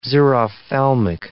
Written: xerophthalmic

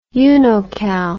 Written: unocal

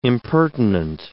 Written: impertinent

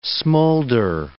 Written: smolder

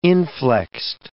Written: inflexed

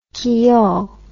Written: quillon

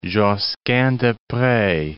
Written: Josquin des Prez

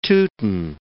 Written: Teuton

